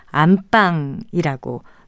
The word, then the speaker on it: 안방: 하지만 사랑방과는 다르게 그 집의 안주인이 거처하는 방은 안방이라고 된소리로 발음합니다.